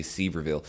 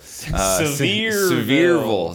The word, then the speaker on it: Sevierville: I always want to say Sevierville. – Sevierville is how that's pronounced.